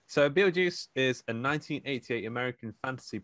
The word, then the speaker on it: Betelgeuse: pronounced on occasion spelled Beetlejuice, from the Neverworld, who tries to scare away the new inhabitants.